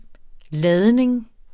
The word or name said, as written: ladning